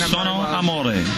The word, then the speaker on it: Io sono amore: Io sono amore. That's how you say it in Italian.